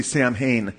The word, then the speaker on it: Samhain: Now, if you read about Samhain, you'll read it as S-A-M-H-A-I-N typically. So if you sounded out, you'd probably say Samhain, but it's pronounced Samhain.